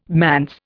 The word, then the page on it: manse: Indian